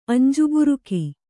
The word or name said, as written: ಅಂಜುಬುರುಕಿ